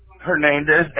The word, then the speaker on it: Hernandez: Hernandez, H-E-R-N-A-N-D-E-Z. – Okay. And you spell it A-R-M-A-N-D-O or O-N-D-O? – A-R-M-A-U-N-D-O.